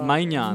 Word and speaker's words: Maignan: Maignan.